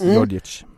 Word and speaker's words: Djordjic: Men så är det också lite oväntade gäster, som till exempel Bojan Djordjic. Är det så det uttalas? – Djordjic.